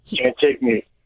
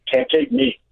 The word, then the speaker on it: Cantigny: Cantigny. Cantigny. But it's like you kind of don't pronounce the knee part.